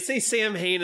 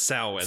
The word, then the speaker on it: Samhain: I can never pronounce it. I know it's not Samhain. That's... Yeah. It's Samhain. – Well, they say Samhain in the movies, but it's pronounced Samhain.